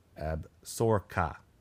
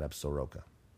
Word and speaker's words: Absaroka: Also, it's pronounced Absorka. Absorka. Not Absoroka. Okay.